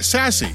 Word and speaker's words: SASE: Pronounced sassy, or as I like to call it, sassy.